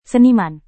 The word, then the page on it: seniman: Indonesian